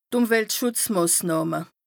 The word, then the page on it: d' Ùmweltschùtzmàsnàhme: Bas Rhin